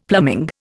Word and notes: plumbing: Indian

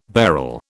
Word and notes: beryl: British English

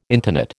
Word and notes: intonate: American English

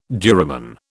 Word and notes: duramen: British English

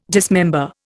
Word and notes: dismember: Indian

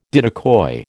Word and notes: diddicoy: American English

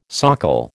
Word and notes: socle: American English

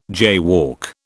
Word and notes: jaywalk: British English

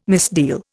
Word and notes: misdeal: Indian

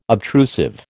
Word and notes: obtrusive: American English

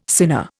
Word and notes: sinner: Indian

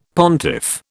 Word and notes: pontiff: British English

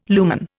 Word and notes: lumen: Indian

